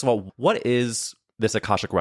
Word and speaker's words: Akashic: Can you tell me, first of all, you say that you offer Akashic Records.